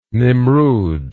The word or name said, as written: Nimrud